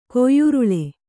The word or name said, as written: ಕೊಯ್ಯುರುಳೆ